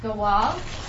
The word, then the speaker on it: Gaal: Because the first word we're going to look at is pronounced Gaal. It's a Hebrew word.